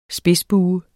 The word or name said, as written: spidsbue